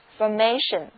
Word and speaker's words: formation: 注意这里的字母 a 读音又由梅花 A 回到了它的本音 formation, formation。